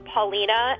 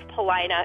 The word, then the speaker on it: Paulina: Curious City listener always pronounced two Chicago streets the way they seemed to be spelled. But her parents, who grew up on the South Side, made fun of her. – I remember them laughing at me for saying Paulina and Throop instead of Paulina and Troop.